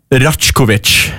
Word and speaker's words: Rajkovic: Det er Nikita sin ballsyn fra, skal vi se om jeg klarer å uttale riktig, Rajkovic. R-A-J-K-O-V-I-C, Rajkovic, det er det jeg kaller han.